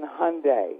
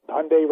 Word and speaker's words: Hyundai: So I have to say, just think Sunday and then Hyundai. – That's right. Yes. – Okay? – Yes. – So it's the Hyundai. – Hyundai rhymes with Sunday. That's right.